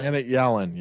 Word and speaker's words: Janet Yellen: Janet Yellen, yes.